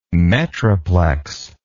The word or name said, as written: metroplex